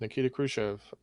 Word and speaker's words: Nikita Khrushchev: The correct answer is Nikita Khrushchev.